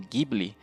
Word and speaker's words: Ghibli: que originalmente é pronunciado como Ghibli, né, remete, acho que é uma palavra italiana, se me engano, remete ao vento que sopra no deserto Saara.